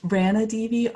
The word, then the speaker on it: Ranadive: It was happening in that first class that you took with Gail Ranadivi. Is that how you say her name? – It's Ranadivi, R-A-N-A-D-I-V-E.